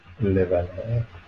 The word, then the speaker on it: LaValle: Victor Laval? Laval? – I'd say Laval. – Lavalle? – Lavalle.